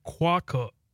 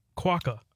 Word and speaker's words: quokka: Okay, so it's quokka. Quokka.